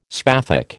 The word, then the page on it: spathic: American English